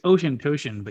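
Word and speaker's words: Auchentoshan: It's Ocean Toshin, but yes. – Ocean Toshin? – Yeah. That's one way of pronouncing it. – It's like, because I was there and they did not pronounce it Ocean Toshin.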